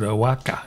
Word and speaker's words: Oaxaca: Cale Tyson, and you write it Oaxaca, but you pronounce it Oaxaca.